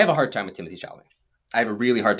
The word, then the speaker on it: Timothée Chalamet: Am I saying that right? – Timothée Chalamet. – That's it.